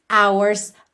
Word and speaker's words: hours: Então, eight hours Hours começa com H, não é vogal, mas esse H não é pronunciado. Então, o primeiro som que a gente tem da palavra hours é o som da letra O.